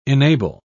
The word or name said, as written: enable